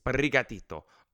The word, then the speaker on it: Sprigatito: And if you can't roll your R's, I'm sorry, because that's the best way to say that name, is Sprigatito.